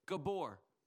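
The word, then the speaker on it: gibor (גיבור): Because here it's an, I think I'm going to pronounce it right in the Hebrew. The word mighty here in the Hebrew is a gibor. That's it. That's what he says, gibor.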